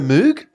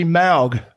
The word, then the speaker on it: Moog: Oh, don't you mean the Moog? – No, it's actually Moog. Well, I've got a Moog album at home that's got the proper pronunciation, and it's M-O-W-G. – Oh, so there you go. It's Moog.